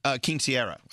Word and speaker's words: quinceañera: But someone said I'm mispronouncing quinceañera. – Quinceañera. – Oh, quinceañera. I'm sorry. I'm sorry. Quinceañera. Is that right? – Yes, correct.